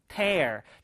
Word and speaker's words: tear: 밑에 거는요, tear. 자, 발음이요, 스펠링이 똑같은데 발음이 다르죠? 자, 여러분, 명사로 쓰일 때는 tear, 동사로 쓰였을 때는 tear이라고 할 수가 있는데요. 자, 요거, 발음 구분해서 외워두셔야 돼요.